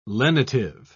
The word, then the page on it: lenitive: British English